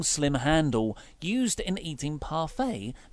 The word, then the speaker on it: parfait: I want to say parfait. – That's correct. – Good. Because it's spelt like the French just trying to, so they can laugh at everyone else reading their words phonetically. Parfait. Or yeah, parfait. – No. Yes, it's parfait.